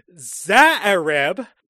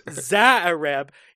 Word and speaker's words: Xuereb: And the host of this little drug and hooker fueled shindig is Eddie Dominguez, played by Emmanuel Zaareb. His last name is spelt XUEREB. That was another one where I had to look up the pronunciation.